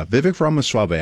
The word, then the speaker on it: Vivek Ramaswamy: Yeah, Vivek Ramaswamy.